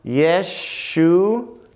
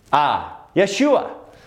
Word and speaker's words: Yeshua: and it is pronounced Yeshua. Yeshua.